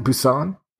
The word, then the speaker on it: Busan: Busan? – Yeah, I would say so.